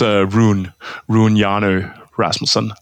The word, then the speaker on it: Rune Hjarnø Rasmussen: My name is Rune Hjarnø Rasmussen. So okay. You mostly just pronounce my name in English because, makes more sense like that.